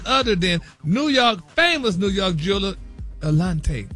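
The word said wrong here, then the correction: Eliantte: Eliante. – Oh, that's how you pronounce it?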